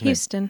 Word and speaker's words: Huston: Danny Huston, or Houston? How do you say that actor's Houston. – Name? – Houston.